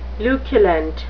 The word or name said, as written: luculent